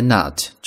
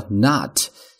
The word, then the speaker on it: knot: 但在这儿呢，我们要讲一个不一样的表达，我们叫做 tie the knot。注意啊。 tie 呢，在这是一个动词，系的意思，比如说系鞋带、系领带等等。第三个词读作 knot，开头的 key 是不发音的，读作 knot。